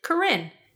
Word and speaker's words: Karin: So it's spelled K-A-R-I-N. What if you keep the name, you pronounce it Corinne? Hi, I'm Corinne.